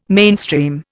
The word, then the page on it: mainstream: Indian